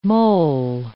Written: mole

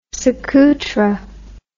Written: suqutra

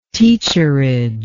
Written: teacherage

